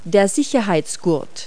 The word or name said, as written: seat belt